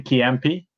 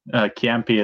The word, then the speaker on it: Ciampi: So Toni Ciampi. Ciampi is spelled like C-I-A-M-P-I.